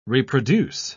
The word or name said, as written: reproduce